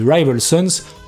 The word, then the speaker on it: Rival Sons: Alors on va se mettre d'accord tout de suite, oui, ça se dit Rival Sons, mais je vais le prononcer à la française, je gagnerai un petit peu de temps et un petit peu d'énergie.